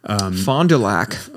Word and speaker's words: Fond du Lac: Fond du Lac. – Fond du Lac, I think it was beautiful. Fond du Lac? – That's how they say it in Wisconsin.